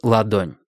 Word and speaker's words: Ладонь: Сейчас форт был наполовину закрыт серым корпусом крейсера авиаматки «Ладонь». Название авиаматки полагалось говорить с ударением на «А», но все называли ее «Ладонь» или «Ладошка».